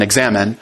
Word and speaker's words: examen: And that's not a typo. That's how it's spelled, not examine, like our English word, I-N-E, but it ends just E-X-A-M-E-N, examine.